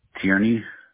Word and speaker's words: Tierney: Ryan, R-Y-A-N. – All right. And would you mind sharing your last name? – Tierney, T-I-E-R-N-E-Y.